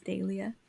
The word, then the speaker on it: dahlia: That's how it's, I think that's how it's pronounced in England, is dahlia.